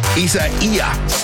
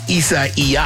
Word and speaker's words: Isaias: Hurricane Isaiah, as another way to pronounce it, is barreling down. You can say it two different ways, depending on... – You just leave the S off the end of it? – You can say the S or us.